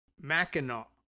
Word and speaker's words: Mackinac: It is that the island's name, I guess, in Michigan is pronounced Mackinac.